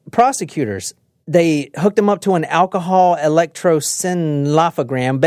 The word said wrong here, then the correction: Electroencephalogram: Basically, that measures brain waves. I can't pronounce it. Electroencephalogram.